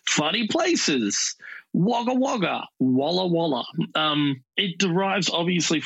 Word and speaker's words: Wagga Wagga: I called it Wagga Wagga, and apparently it's not that. And no. – What is it? – And that's 100% how it's spelled, but it's pronounced Wagga Wagga.